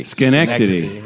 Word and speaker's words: Schenectady: So there's no road west out of, I don't know how to say this, but Schenectady. – Schenectady. – Schenectady. Yeah. Schenectady.